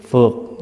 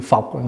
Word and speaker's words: phược: 72 phẩm kiết phược, là chữ phọc, người Bắc đọc là phọc, mình đọc là phược.